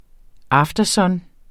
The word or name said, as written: aftersun